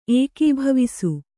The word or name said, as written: ಏಕೀಭವಿಸು